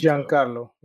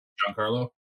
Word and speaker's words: Giancarlo: Giancarlo, yeah. – Giancarlo.